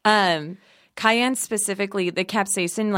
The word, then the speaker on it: capsaicin: Yeah, capsaicin.